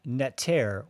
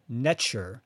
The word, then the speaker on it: Neter: So the sacred double-headed axe, which is called the Egyptian Neter or Netcher, which is spelled N-E-T-E-R. And in the Egyptian language, there was no vowels, so it was just spelled N-T-R.